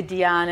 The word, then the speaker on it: Diane: They say Diane, and it rhymes with banana, like Diane Banana.